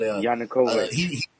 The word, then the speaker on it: Yanukovych: But Yanukovych.